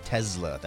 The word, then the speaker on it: Tesla: Looking at the shares of Tesla, and that's how you pronounce it, Matt, Tesla, that's how Elon Musk pronounces it.